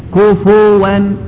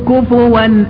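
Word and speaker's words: كُفُوًا: من الأخطاء الشائعة تسكين الفاء، كفوا، غلط، كفوا كفوا، الفاء مضمومة في القراءتين،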